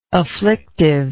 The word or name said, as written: afflictively